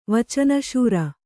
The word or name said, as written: ವಚನಶೂರ